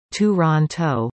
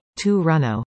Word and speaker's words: Toronto: The right way to pronounce it for non-natives is Toronto, although locals will usually say Toronto.